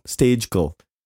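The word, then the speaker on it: Stejskal: We really should figure that out for Stejkel. – Real. Stejkel. Like Dojkel. It's funny.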